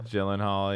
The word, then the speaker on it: Gyllenhaal: Is that how you say it? Is Gyllenhaal the way you do it, or is it Gyllenhaal? – Rust around. – Gyllenhaal, yeah. Gyllenhaal, whatever his name is.